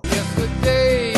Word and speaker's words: yesterday: Yesterday and days before. – Yesterday. Tres sílabos ahí, enfatizando la primera.